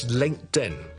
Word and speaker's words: LinkedIn: The second one is LinkedIn. LinkedIn. It comes from linked, L-I-N-K-E-D, linked, 有个 T 音在这里，其实 L-I-N-K-E-D, 个 E-D 是个 T 音，那扔埋个 N 就会读成 LinkedIn, LinkedIn, LinkedIn, OK? 就不是 LinkedIn。那么第三个就是 WhatsApp, 不是 WhatApps, but WhatsApp, WhatsApp, WhatsApp, 扔埋的 WhatTS, 扔埋个 App 就变成 WhatsApp。所以讲到最后一次 ,Uber, LinkedIn and WhatsApp。